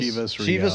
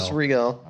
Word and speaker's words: Chivas Regal: How do you pronounce it? Is it Chivas? – Chivas Regal. – Chivas Regal.